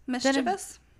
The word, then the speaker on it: mischievous: No, you pronounce it mischievous. You can look that up right now. I will bet you a dollar. – I don't like mischievous. – I cannot help that that's how it's pronounced.